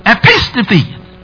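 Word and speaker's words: Epistephe (ἐπίστηθι): Epistephe. Epistephe. Epistephe. Which is translated, publish or preach the word. Be attentive. That word, there is a verb there. Is epistephe. Epistephe. Accent is on the second syllable there.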